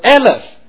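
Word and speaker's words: Aleph (אלף): 在这里也请弟兄姐妹让我稍微解释一下，这里所谓的 4000人在原文里面是指的四个加上一个字，那个字呢在希伯来文里面它的发音叫做 Aleph。 Aleph 这个字在希伯来文里面可以翻译成千这个数字，所以就说死了大约 4000人。可是这个 Aleph 这个字又还有另外一个意思，它是指着某一种的编制的单位，特别是指着某一种的军事的编制单位。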